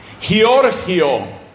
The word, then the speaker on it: Giorgio: Giorgio, que en italiano se pronuncia Giorgio.